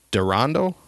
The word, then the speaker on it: Darondo: And I think this is pronounced Durando. D-A-R-O-N-D-O. – Durando.